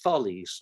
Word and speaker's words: Thales: no, it's Thales. And I said, how do you know? And she said, well, because the company sent someone around to everyone who was selling it, telling them how to pronounce their name. So it's Thales.